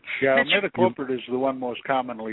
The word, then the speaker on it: imidacloprid: Imidacloprid is the way you pronounce it.